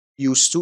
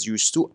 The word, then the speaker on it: used to: Used to این رو میرسونه که اون حالتی که ما میگیم بعد از used to الان وجود نداره. و تلفظش used to هست. خواهش میکنم use تلفظش نکنید. Used to. Used to. و تی او تو هم بسیار خفیف تلفظ میشه.